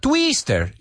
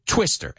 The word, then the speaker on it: twister: So it's not twister. You hear how that I, first of all, doesn't sound like E. It's I, twister, and it's a short vowel sound.